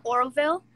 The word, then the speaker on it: Oroville: It's called Oroville. – Can you spell that? – O-R-O-V-I-L-L-E.